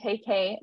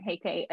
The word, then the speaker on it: Huyke: My last name is spelled H-U-Y-K-E. And then you can find me on LinkedIn at Bree Godwin Huyke. Again, Huyke is spelled H-U-Y-K-E. Doesn't look like how it's pronounced, but that's how you pronounce it.